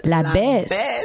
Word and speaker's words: Labess: T'avais envie aussi de nous parler d'un autre artiste que tu me fais découvrir, ouais, La Besse. Qui s'écrit L-A-B-E-S-S. Si vous cherchez sur Google ou YouTube, c'est La Besse, ouais.